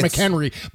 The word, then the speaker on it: McHenry: which is mispronounced. Well, it's Fort McHenry, but he said McHenry on the 4th of July.